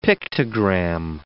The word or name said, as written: pictogram